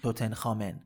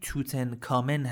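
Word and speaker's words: توتن‌خامن: توتن خامن البته تلفظ دقیقش در انگلیسی توتن کامن هست توتن کامن که ما حالا یه ذره تغییرش میدیم به همون توتن خامن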